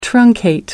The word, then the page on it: truncate: American English